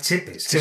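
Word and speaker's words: Țepeș: Vlad Tepes es realmente Vlad Chepes, es como se pronuncia en rumano.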